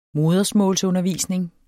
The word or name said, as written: modersmålsundervisning